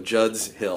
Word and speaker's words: Judd's Hill: Judd Hill's Winery. – Now the first one, possessive, Judd's Hill. – Oh, Judd's Hill Winery.